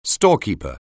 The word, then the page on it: storekeeper: anglais